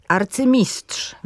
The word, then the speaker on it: arcymistrz: Pojawia się on w połączeniach jednosylabowych rzeczowników z cząstkami arcy, ex i vice. Na przykład arcymistrz, wicekról czy eks-mąż. Podkreślam, że w tych wszystkich formach można również akcentować drugą sylabę od końca, z tym, że są to formy wtedy uznawane za potoczne.